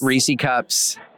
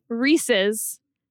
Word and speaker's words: Reese's: Reese cups is an act of love. – He pronounces them Reese cups when it's clearly Reese's. And we know that because they're advertised.